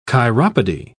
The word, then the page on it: chiropody: British English